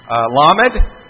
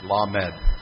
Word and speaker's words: Lamed: Lamed, which is the L in lucky, pronounced like llama, or law, like you're a lawyer, and med, like the medicine you take, law med.